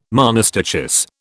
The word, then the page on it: monostichous: British English